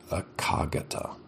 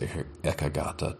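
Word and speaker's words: ekaggatā: Some people, pronounce it akagata. I've heard it pronounced ekagata, too.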